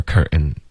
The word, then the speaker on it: curtain: I straightened out your shower，然后后面呢 curtain 的这个 ten 特的音也会用一个喉音停顿，所以就发 curtain shower curtain。